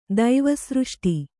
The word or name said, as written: ದೈವಸೃಷ್ಟಿ